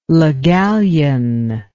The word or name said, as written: le gallienne